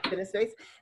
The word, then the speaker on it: CineSpace: She does work with CineSpace. Did I say that correctly? – CineSpace.